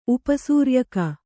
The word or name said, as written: ಉಪಸೂರ್ಯಕ